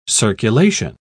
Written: circulation

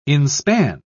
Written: inspan

